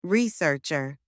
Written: researcher

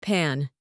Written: pan

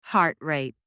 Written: heart rate

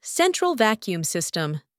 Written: central vacuum system

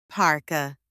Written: parka

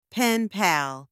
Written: pen pal

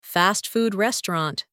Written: fast food restaurant

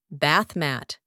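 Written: bath mat

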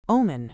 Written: omen

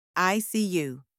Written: ICU

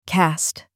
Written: cast

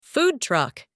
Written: food truck

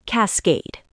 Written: cascade

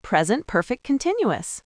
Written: present perfect continuous

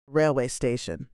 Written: railway station

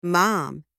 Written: mom

